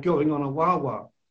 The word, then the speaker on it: guagua: They have buses in Tenerife near the Canary Islands that they call Wawa, which is G-U-A-G-U-A, and you pronounce that Wawa.